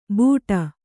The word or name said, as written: ಬೂಟ